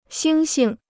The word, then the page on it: 猩猩: xīng xing